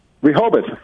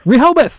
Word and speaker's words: Rehoboth: How do you say that town? – Rehoboth. – Rehoboth. There we go.